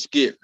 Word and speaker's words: Skypp: So my name is Skip. That's spelled S-K-Y-P-P.